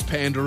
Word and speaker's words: Panda: Saying Panda as if it's P-U-N-D-A.